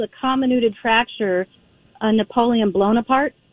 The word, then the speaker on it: comminuted fracture: Okay, so first of all, are we saying it correctly? Comminuted fracture? – Yes, you are.